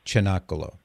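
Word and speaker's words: Cinacalo: Yeah, it's just because the C in Italian at the beginning of a word that way would be pronounced Chinakalo.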